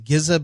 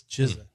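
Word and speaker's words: GZA: I used to pronounce it Gizza, but it's Jizzit.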